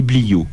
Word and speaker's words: bliaud: Alors les tuniques, chins et bliots. Chins, ça s'écrit C-H-A-I-N-S-E et bliots, B-L-I-A-U-D.